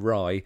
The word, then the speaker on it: Raí: Yeah, just in case anybody's not sure, you did use the local pronunciation for his name, but of course a lot of people here will call him Rye. That's phonetically spelled, but you are absolutely right, Joshua.